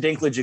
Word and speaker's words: Dinklage: Am I saying that wrong? Is there a proper way to say that? – We're good. – No, you said Dinklage exactly right.